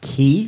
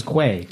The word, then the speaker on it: quay: to tour around Dublin and things, and he's like, wait, that's pronounced key? He thought it was quay.